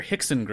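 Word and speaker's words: Rickson: His older brother, Hickson Gracie, was. Yes, his name is spelled with an R, but is pronounced H because Portuguese in Brazil is weird.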